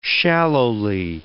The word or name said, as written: shallowly